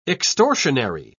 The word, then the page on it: extortionary: British English